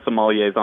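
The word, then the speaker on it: sommelier: Saumonier, is that how you pronounce it for a wine specialist? – Absolutely. We have I believe 6 or 7 sommeliers on staff now, including myself.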